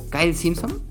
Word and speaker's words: Kyle Simpson: Se llama you, don't, Know JS y el autor es Kylie Simpson. Kyle Simpson, espero, espero, pronunciarlo bien, Kyle, Kyle Simpson.